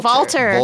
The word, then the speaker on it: Walter: It's spelled with a W, but it's pronounced like a V. – Oh, Walter.